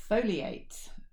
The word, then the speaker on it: Foli8: And another one for your interest, Foliate. No, it's spelled F-O-L-I, figure eight.